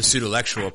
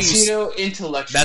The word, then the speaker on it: Pseudolectual: I'm so happy that you're saying it correctly. Thank you. – What did I used to say? Because I know you... – Pseudo-intellectual. – That's right, yeah. Pseudo-intellectual.